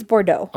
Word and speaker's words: Bordeaux: Well, is there an R in between after the D? I think it's Bordeaux.